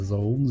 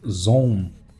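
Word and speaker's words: Zaun: Я все время душу за название Заун, Заун а он произносится как «зон». И вот не знаю, почему решили называть «заун», «заун».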